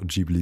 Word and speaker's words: Ghibli: also Ghibli oder auch Ghibli genannt, kommt auf die Person an. Ich hörte beides ist richtig in der Aussprache.